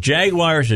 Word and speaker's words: Jaguar: I guess if you say it the English version, it's Jaguar. – Yeah. – Is how they say it.